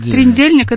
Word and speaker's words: трдельник: Я не понимаю тогда, как это произносится. – Трдельник. – Первая буква какая? – Трдельник. А слово трдло.